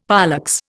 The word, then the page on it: pollex: Indian